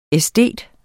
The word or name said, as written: æstet